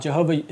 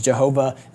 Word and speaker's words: Jehovah: Jehovah is actually just the German pronunciation of YHWH, which had no vowels, along with the vowels from Adonai, so that we could say it. You can't say YHWH, right? We kind of say Yahweh, but that's where that term came from. So it's just the German pronunciation of Yahweh, okay?